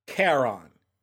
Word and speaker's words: Charon: Looking up the actual pronunciation of Charon, the ferryman from Greek mythology. Here we go. Pronounced Charon. Okay, now I know.